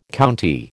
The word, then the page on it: county: American English